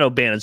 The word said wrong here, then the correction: Gannon: sorry, it's Gannon. I mispronounced his last name.